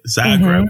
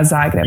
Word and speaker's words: Zagreb: Zagreb. – Mm-hmm. Yeah, Zagreb.